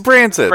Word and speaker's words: Branson: It's Branson.